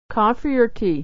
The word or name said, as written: or tea